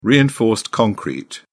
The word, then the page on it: reinforced concrete: British English